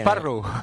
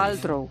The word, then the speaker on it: Paltrow: Pero no es Parrow. – ¿Cómo es? ¿Cómo se dice? ¿Cómo se Paltrow. – Dice? – Como el coche.